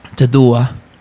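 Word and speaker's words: të dua: Tadua. – Oof. – Love it. – Tadua. – Tadua. Yeah, that's how you say it.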